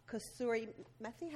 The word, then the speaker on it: kasuri methi: You've got gram flour, turmeric, Kashmiri chili powder, garam masala, kasuri methi.